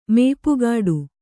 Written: ಮೇಪುಗಾಡು

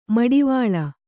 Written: ಮಿಡಿವಿಲ್

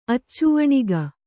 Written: ಅಚ್ಚುವಣಿಗ

